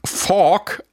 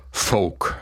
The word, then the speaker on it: Folk: Das Wort Folk wurde in euren Podcasts schon des Öfteren von mehreren Redakteurinnen wie Fork ausgesprochen. Richtig ist aber natürlich Folk.